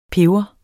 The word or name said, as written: peber